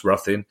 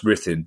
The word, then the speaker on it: Ruthin: It's not pronounced Ruthin, it's pronounced Rithin.